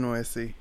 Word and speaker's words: Nuesi: Brian Nuesi. – Brian Nuesi. That sounds better when you say it. – Yeah. – Because you say it right.